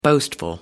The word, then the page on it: boastful: American English